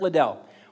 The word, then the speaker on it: Liddell: Eric Liddell or Little? How do we say it? Eric Liddell.